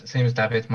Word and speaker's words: Dawid: It's my Instagram name is David_performance_fitness, and David is spelled DAWID. So if you want to follow me, you're more than welcome to. – Nice. And yourself, George? – Yeah, same as David.